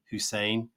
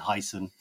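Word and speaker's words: Hysén: Old-time football player, used to play for Liverpool, Glenn Hussein. That's how you say it. Glenn Heisen, as the British commentators would say.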